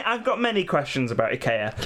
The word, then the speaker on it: IKEA: IKEA, IKEA, as it's pronounced. – That is how it's pronounced.